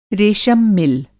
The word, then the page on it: रेशम मिल: Hindi